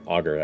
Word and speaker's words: Augur: Is there a significant present of glass blades on the Augen Trail from what we can see? – The Augur Trail, as you look around... – Augur or Augen? – Sorry, Augur. I said it wrong originally.